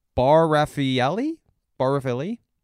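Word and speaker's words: Bar Refaeli: Kate Moss, Helena Christensen, Naomi Campbell, Bar Raffaelli? – Yeah. – Okay, wow, I said that properly.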